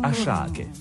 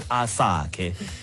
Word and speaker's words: Asake: So the S is Sh. – Oh. – Ashake, not Asake.